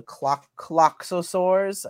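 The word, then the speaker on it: Klaxosaur: Cloxosaurs. I think that's how they pronounce them. Cloxosaurs, whatever. It's spelled like K-L-A-X-O and then Sar, like a dinosaur.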